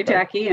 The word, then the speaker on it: Jacke: You can find me either on LinkedIn, Terry Jackie, and Jackie is spelled J-A-C-K-E. Sounds like two first names.